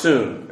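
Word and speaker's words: soon (σύν): And I understand, if you understand, also in spelled in the Greek, you have an S-U-G, but it's pronounced soon. But they didn't like two hard consonants together, so they just phonetically would translate it as soon.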